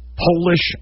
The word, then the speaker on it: Polish: It's Polish and polish. It's spelled P-O-L-I-S-H, Polish and polish.